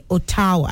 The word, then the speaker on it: Ottawa: if you're going to pronounce that word using the Yoruba intonation, it's Ottawa. But no, that's not how it's pronounced. The capital of Canada is Ottawa, okay? Ottawa. And it's spelled O double T A W A.